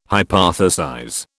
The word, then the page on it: hypothesize: British English